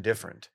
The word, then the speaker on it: different: How many syllables in different? – Three, technically, but people say it as if it were two. – Different. – Different is how people say it, but it's different. – Let's make a pledge right now. Let's all take a pledge that we will, next time we say beat the shit out of them. – Different. We will all emphasize all three syllables because all three deserve their proper time.